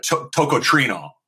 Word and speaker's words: tocotrienol: So you pulled out the carotene, and what was left was essentially a form of vitamin E or a tocotrienol, if I'm pronouncing it correct.